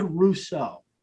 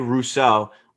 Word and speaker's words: Rousseau: Greg Rousseau. – Greg Rousseau.